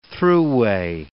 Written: thruway

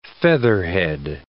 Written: featherhead

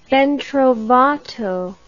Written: ben trovato

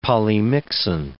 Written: polymyxin

